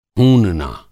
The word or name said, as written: हूनना